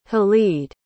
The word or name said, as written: halid